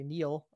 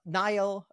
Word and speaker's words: Nile: I always want to say Neil, the German pronunciation of it. We talked about that before in the pre-run to that session. Maybe tell us a little bit about Nile, your serverless Postgres installation, and why it is called Nile and not Neil.